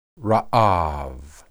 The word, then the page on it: raab: hebrew